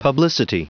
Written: publicity

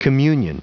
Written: communion

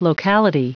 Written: locality

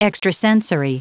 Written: extrasensory

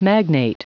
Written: magnate

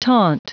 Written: taunt

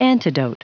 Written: antidote

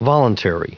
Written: voluntary

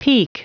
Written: pique

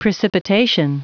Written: precipitation

